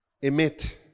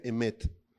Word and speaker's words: emet: Hay una palabra en hebreo, emet, emet, se pronuncia emet no se escribe igual. Recuerde que las letras en hebreo son muy distintas. Emet se pronuncia así.